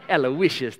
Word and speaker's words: Aloysius: It's like Aloysius. Aloysius, that's my name. Aloysius. You gotta really have some rhythm to say it, Aloysius.